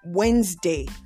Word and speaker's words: Wednesday: You hear people say Wednesday. It's not Wednesday. It's not Wednesday, but it is pronounced Wednesday. Wednesday, not Wednesday. It is Wednesday. Wednesday is the correct thing to say.